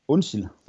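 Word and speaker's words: Onsild: Er, hedder det Onsil eller Onsil? – Onsil. – Onsil. Ja, der kan du bare se.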